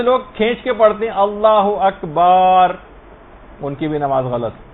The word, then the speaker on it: اللہ اکبر: بہت سارے غلط تلفظ لوگوں کے اندر رائج ہیں، مثلا اللہ و اکبر، اللہ اکبر ہے یہ، اللہ و اکبر نہیں.